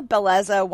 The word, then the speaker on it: Bellezza: But Chef Antonio is going to teach you how to eat like an Italian at Villa Bella, Belza? Beleza? Villa Beleza? I don't know. I know my friend Amy knows how to say this. I don't. But Villa, Villa Bellezza Winery and Vineyard in Pepit. Okay? Sorry. Took me a while to get there, but I did.